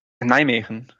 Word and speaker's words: Nijmegen: Nijmegen.